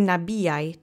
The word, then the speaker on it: nabijaj: So, let's say nabijaj together three times. Accent is on the middle syllable.